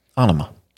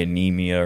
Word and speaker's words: Anima: That's how you pronounce it? – Anima. – Anima. I've been calling it something else for years. – What have you been calling it? – Probably Anemia or Nema or something like that.